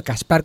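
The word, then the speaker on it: Caspar: Y el dibujante, el apellido es difícilmente pronunciable, de nombre es Kaspar, no Gaspar con G, sino Kaspar con C, Kaspar Wingard.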